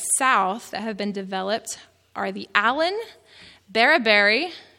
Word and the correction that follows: Bababerry: I think I'm saying that right. I am from Texas. No, I'm not saying it right. It's Bababerry. Bababerry.